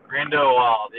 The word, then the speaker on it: Grindelwald: Grindelwald, yeah.